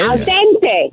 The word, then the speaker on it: al dente: And you said al dente. – Oh, stop it. – I almost had a car accident. – Oh, yeah, because it's so important to you. If you're going to use an Italian word, you got to make sure you pronounce it right. – Sure. – Which is? – Al dente. – Al dente, yes.